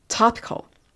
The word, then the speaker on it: topical: えー、それならばあなたは You can try something topical 何かトピカルな薬を使うことができますね。例えばリドカインパッチとかというふうに始めています。ここでトピカルという単語出てきました。発音は topical オープン A でした。